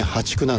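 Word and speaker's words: 淡竹: でこれからは破竹、淡い竹と書いてね、破竹なんですけどね、淡水の淡、淡いですね、これに竹、破竹、そして月末にはマダケといった種類が芽を出してくるということなんですね。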